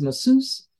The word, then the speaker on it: masseuse: In America, we pronounce MASSEUSE as masseuse. The actual pronunciation, I think, is masseuse, and that is a female who practices massage.